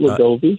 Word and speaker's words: Wegovy: Is it Wegovy? I'm not sure how to pronounce that. – Yep. But you got it right, Wegovy.